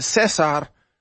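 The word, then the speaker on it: César: Caesar, I'll have his real name here in a minute, but Caesar, whatever you want to call him, in Espanol, you would pronounce it Cesar.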